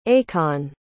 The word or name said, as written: akon